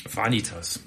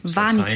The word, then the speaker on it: Vanitas: Vanitas oder Vanitas? Wie würdet ihr es aussprechen? – Vanitas. Vanitas. Latein.